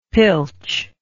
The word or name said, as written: pilch